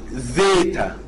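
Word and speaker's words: زيتا (ζήτα): ايه الحرف اللي بعد كده ده حرف زيتا، بتتنطق بميلة زي ما بننطق دي بيتا، بننطق دي زيتا.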